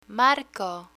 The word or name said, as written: marco